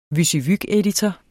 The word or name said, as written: WYSIWYG-editor